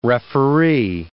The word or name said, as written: referee